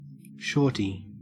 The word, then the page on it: shorty: Australian English